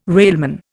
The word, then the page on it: railman: Indian